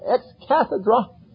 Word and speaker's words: ex cathedra: You know, according to the Catholic Church, when a pope speaks ex cathedra, or ex cathedra, as some pronounce it, that is an infallible statement.